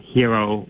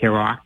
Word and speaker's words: Heroux: They pronounced it Heroux when he was growing up, but he wasn't quite sure. He said some people pronounced it Herox, Herox. Herox is definitely not correct. That yeah. cannot be correct.